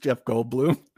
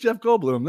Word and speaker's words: Goldblum: It's just Jeff Goldblum. That's all. But it's spelled Bloom. It's not Bloom, like Blum. – It's Bloom, not Blum.